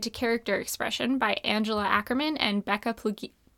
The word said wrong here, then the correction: Puglisi: Oh, I can't say her name. – Plugi? – Becca Puglisi? – Oh, Puglisi.